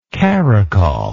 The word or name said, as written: caracoller